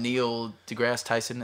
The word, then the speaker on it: Neil deGrasse Tyson: And Neil deGrasse Tyson, is that how you say his name? – Yeah.